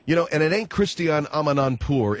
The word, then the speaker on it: Christiane Amanpour: I came on your program to praise Christiane Amanpour, which, by the way, that is her name, Christiane Amanpour. You people have mispronounced it.